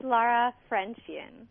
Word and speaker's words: Laura Frenchian: I'll do it. It's Laura Frenchian.